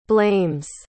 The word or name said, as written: blames